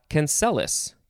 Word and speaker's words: cancellous: Cancelis is next. You could also pronounce it cancellus. Cancellus or cancellus.